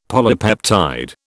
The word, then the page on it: polypeptide: British English